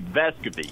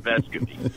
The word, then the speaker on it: Vescovi: You know, he said, I don't care what you call me, but it's actually Vescovi.